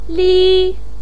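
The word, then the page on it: li1: pinyin; tone 1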